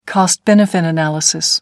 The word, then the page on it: cost-benefit analysis: American English